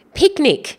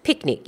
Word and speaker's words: pyknic: Pyknic. Now it's P Y K N I C. P Y K N I C, but pronounced picnic. Yes, sounds exactly like picnic, as in, you know, eating a meal outdoors on a nice checkered blanket and having some taramas.